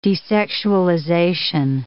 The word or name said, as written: desexualization